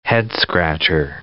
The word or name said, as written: head-scratcher